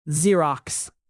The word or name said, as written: xerox